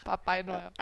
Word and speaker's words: Papai Noel: Yeah, Papai Noel. – Papa Noel. – Papai Noel.